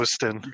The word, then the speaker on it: Costin: So here's where uh, Costin, am I saying his name right? Uh, he uh, reveals like himself, Costin. – Costin.